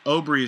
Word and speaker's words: Obree: So the book that I'm reading that that they sent to the podcast is called The Obrey Way, and that's Obrey is spelled O-B-R-E-E, and it's just called A Training Manual for Cyclists.